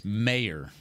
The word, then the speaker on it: Mayer: Michael Meyer. It's Mayer.